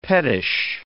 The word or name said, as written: pettish